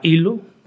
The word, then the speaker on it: ELO: Somos socios de un fabricante alemán que se llama ILO, bueno, se escribe ELO, ILO Digital Office, de ahí la tendencia digital.